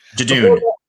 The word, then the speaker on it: Judoon: Judoon.